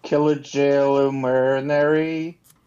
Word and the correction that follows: Kajillionaire: Oh, Kajillionaire.